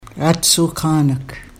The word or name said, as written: aadsookaanag